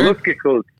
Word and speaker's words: Luftgekühlt: So what is Luftgekühlt? Am I pronouncing that right? Is that correct? – That's a B-. Luftgekühlt. – What do we got? – Is that better? – Luftgekühlt. Yeah.